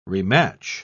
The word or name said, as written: rematch